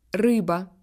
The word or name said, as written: poisson